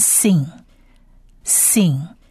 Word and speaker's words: sin: 而这个山这个字呢，它如果点点，它的黑点是在左上方的话，这个字念 sin sin，而在发音上是发 s 四的音。